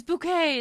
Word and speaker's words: Bouquet: It's bouquet. It's pronounced bouquet.